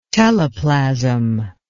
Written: teleplasm

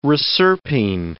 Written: reserpine

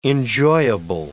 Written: enjoyable

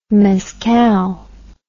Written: mezcal

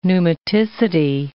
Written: pneumaticity

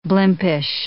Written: blimpish

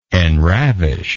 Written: enravish

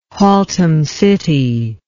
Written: Haltom City

